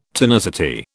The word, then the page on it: tonicity: British English